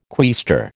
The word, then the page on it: quaestor: American English